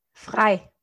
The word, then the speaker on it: frei: Frei.